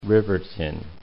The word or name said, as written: Riverton